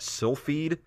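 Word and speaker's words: Silpheed: How do you say that? Sylphide, Yeah, that sounds right.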